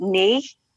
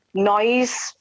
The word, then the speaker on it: NNYZ?: So case in point, we've got a really lovely musician who sends work, and it's spelled N-N-Y-Z with a question mark. And I'm like, how do I say that? And he goes, oh, you pronounce it knee. So I need to know these things, because otherwise I'm going to say noise.